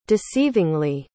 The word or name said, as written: deceivingly